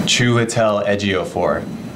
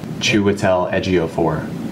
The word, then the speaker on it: Chiwetel Ejiofor: Ja, ich hätte noch die Info, wie Chiwetel Ejiofor ausgesprochen wird. Warte, mal sehen, ob ich es einspielen kann. – Chiwetel Ejiofor. Chiwetel Ejiofor.